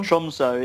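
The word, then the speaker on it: Tromsø: You made me pause and think when I looked at this, what's it, Tromso? Is that how you pronounce it? Tromso? – Tromso.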